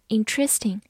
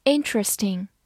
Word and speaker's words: interesting: 注意重音放在第一个音上，不要读成 interesting。 interesting。